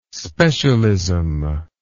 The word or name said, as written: specialism